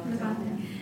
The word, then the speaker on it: Levante: So is the CEO and founder of Levante. Have I pronounced that? – Levante.